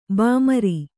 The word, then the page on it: ಬೆಮರ್: bemar